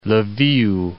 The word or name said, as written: L'viv